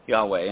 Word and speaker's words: Yahweh: Now, the name, to the best of our estimations, is pronounced Yahweh. Yahweh, and I'll comment on that estimation a little more in a few minutes.